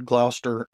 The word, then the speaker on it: Gloucester: I saw that Gloucester, and I wondered how you were going to pronounce that. The way you said it, I was thinking it was the right way,